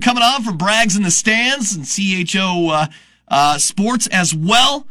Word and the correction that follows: CHGO: Uh, I just totally butchered that because I got all excited about it. CHGO. Sorry. Hey, look, I didn't take the vowels out the word.